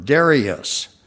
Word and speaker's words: Darius: I'm finding there's a long A in that word, Darius.